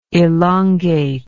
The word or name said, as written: elongative